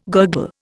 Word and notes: gurgle: Indian

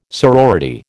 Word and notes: sorority: American English

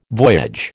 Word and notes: voyage: American English

